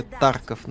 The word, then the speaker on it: Тарков: Ударение Тарков на первый слог. В на Таркове.